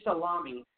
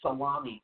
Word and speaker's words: Salami: General Hussein Salami, what a last name, Salami, and that's how it's spelled, like the meat salami, S-A-L-A-M-I, also cautioned Iran does not have any intention for war with any country, but we are ready for war.